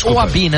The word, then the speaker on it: Ouzabi: Contratação do Mohamed Ouzabi para substituir Walid Regragui como treinador. Então a gente traz essa informação. – Falei errado o nome dele, né? – Ouzabi, né? – Ouzabi, tá bom.